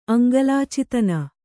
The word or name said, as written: ಅಂಗಲಾಚಿತನ